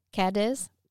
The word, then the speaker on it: Cadiz: How do you pronounce that? Cadiz? C-A-D-I-Z? Cadiz? – Cadiz, I would say.